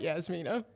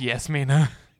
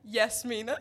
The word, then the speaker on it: Yasmina: Is now a good moment to mention that my name's not Yasmina? – Wait, what? – It's Yasmina. – What? Say it again. – Yasmina.